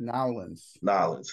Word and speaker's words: New Orleans: If I said that properly, I don't know. I'm sorry if I did. – New Orleans. – There we go.